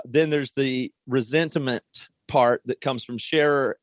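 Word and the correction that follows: Scheler: or I don't, how do you say his name? Scherer? – Scheller.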